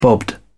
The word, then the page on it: bobbed: British English